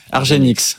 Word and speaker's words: Argenx: Dans le segment de la santé, vous suivez Argenx, c'est comme ça qu'on prononce. – Argenx. Voilà, exactement.